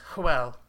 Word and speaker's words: Hywel: I think it's Huel. – Huel? okay. – Same pronunciation, different spelling.